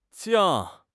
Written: jiang4

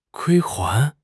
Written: 归还

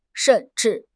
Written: shen4 zhi4

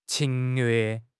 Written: 侵略

